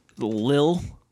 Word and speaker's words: Lil: It's Lil L-I-L, Lil Pump. – Lil Pump. – Not little. – Lily. – You don't pronounce the little. – Like a lily. Like a lily pad.